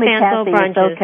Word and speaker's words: Fanslow-Brunjes: Kathleen Fanslow-Brunjes.